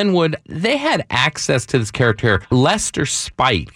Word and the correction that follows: Spate: Is that how you pronounce it? The guy who worked for a company that Spate. You created? Spate.